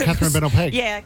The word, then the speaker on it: Catherine Bennell-Pegg: Catherine Bennell-Pegg.